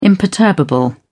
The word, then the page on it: imperturbable: British English